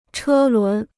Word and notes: 车轮: chē lún

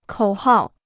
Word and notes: 口号: kǒu hào

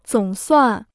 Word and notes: 总算: zǒng suàn

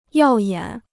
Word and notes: 耀眼: yào yǎn

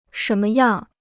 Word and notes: 什么样: shén me yàng

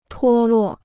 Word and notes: 脱落: tuō luò

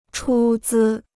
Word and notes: 出资: chū zī

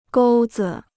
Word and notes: 钩子: gōu zi

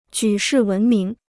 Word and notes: 举世闻名: jǔ shì wén míng

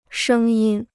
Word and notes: 声音: shēng yīn

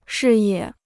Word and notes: 视野: shì yě

